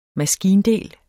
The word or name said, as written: maskindel